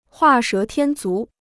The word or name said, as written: 画蛇添足